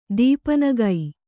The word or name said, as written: ದೀಪನಗೈ